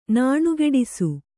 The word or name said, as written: ನಾಣುಗೆಡಿಸು